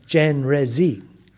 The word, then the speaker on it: Chenrezig: In Tibetan, when they translated it, it became Chenrezig, as it's pronounced in English.